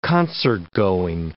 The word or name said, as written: concertgoing